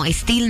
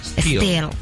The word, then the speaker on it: Still: No, Still no. Still. Porque es S-T-I-L-L. Still. – Ok. – Still. Que la I se pronuncia como E y así.